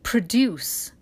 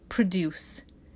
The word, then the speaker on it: produce: Well, you know the verb is to produce, the stress on the second syllable because it's a verb. But what do you produce? You produce produce. Produce is the noun.